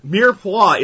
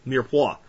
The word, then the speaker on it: mirepoix: Mirepoix is a single word. It's French and it's spelled M I R E P O I X. Mirepoix.